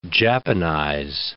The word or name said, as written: Japanize